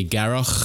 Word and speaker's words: Garroch: Okay, my name is Jamie, or Jamie Garroch. For everybody who wants to know how to pronounce it, go to Scotland and learn how to say loch in a Scottish accent. It's loch.